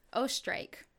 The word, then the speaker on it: Oesterreich: Ostrike. – Ostrike? – Yeah, so let me spell that for you. – Please. – O-E-S-T-E-R-R-E-I-C-H.